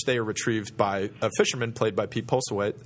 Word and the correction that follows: Postlethwaite: Whose name I can never properly pronounce, and I'm just going to run with that. – Pete Dinglehoffer. – Exactly. – Postlethwaite. – Yeah. Is that what it is? – Postlethwaite?